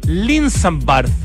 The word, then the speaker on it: Linsambard: Sí, bueno, es Linsambard, pero en verdad se pronuncia con D o con TH final, así que no hay problema.